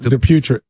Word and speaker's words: Dupuytren: Dupuytren.